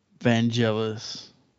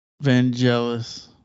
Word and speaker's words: Vangelis: But Vangelis. Vangelis. I think that's how you pronounce it.